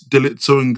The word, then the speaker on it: Dalitso: And then on IG, you can find me either the Business at Heart pod, or it's Dalitso Ngoma, which is D-A-L-I-T-S-O. – That's that. Why did you say your name like that? – Because that's how it's actually said. You know the T silent.